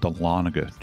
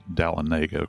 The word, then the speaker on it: Dahlonega: We used to live in a town called Dahlonega, Georgia. People sometimes pronounce it Dahlonega. Of course, that's not the case.